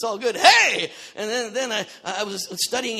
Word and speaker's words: He: Neat thing about the fifth letter of the Hebrew alphabet is you pronounce it, hey.